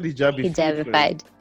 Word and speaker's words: Hijabified: This is through the channel Hijabified. Is that, am I pronouncing yes. – it? – Yes. – Hijabified. – Yes. – As I said, Hijabified. – Hijabified. – Almost like petrified from Harry Potter.